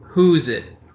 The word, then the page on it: whosesoever: American English